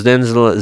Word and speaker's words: Дензел: интервью с, Дензел, с Дензелом, правильно говорить, Дензел Вашингтон, один из самых э, популярных американских артистов – мы будем слушать следующую нашу программу в нашей передаче.